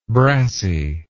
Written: brassey

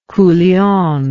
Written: culion

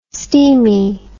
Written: steamie

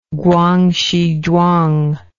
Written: Guangxi Zhuang

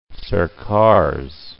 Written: Northern Circars

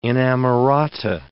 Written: inamorata

